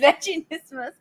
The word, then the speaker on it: Vaginismus: How are we supposed to pronounce it? Vaginismus. Oh, yes, Vaginismus. Okay, oh, that's very good pronunciation, and well really? – Done.